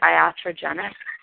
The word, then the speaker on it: iatrogenic: How do you pronounce, is it iatrogenic? When you're talking about the negative effects of the medical model? – I'm looking. – Oh, I think so.